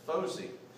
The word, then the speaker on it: Fauzi: So this was my first auditor, and his name is Fozi, F-A-U-Z-I. And I know that like, like me, you probably thought you would pronounce that Fauzi, but that's not correct. He, he made sure to correct me as soon as I met him. It's Fozi.